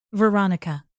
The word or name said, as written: veronica